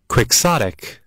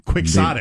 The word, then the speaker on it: quixotic: It spawned some uh, many now common phrases or idioms uh, across Spanish and other languages, including the phrase tilting at windmills and uh, chaotic, uh, which means exceedingly idealistic, unrealistic, impractical. Quixotic. Ah, I said it wrong. – Quixotic.